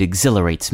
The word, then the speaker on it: exhilarate: Skiing down a snow-covered mountain at high speed exhilarates me. Many people find exhilarate a difficult word to spell. It's easy to forget that silent H in there, and is it ER or AR after the L?